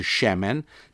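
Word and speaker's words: Shemen (שמן): Now, if we add one letter to the end of that, the letter N for November, we get Shemen, and it's a three-letter word. Spelt S, if I were to transliterate it, I'd spell it S-H-E-M-E-N, Shemen. That means oil.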